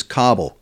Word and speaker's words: Kabul: For the folks that are listening, some people say Kabul, others say Kabul, but the proper pronunciation is Kabul, correct? – Yeah, Kabul.